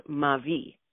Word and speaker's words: Mavi: where my grandmother would ferment a beverage called Mavi. And we pronounced it M-A-V as in Victor, I.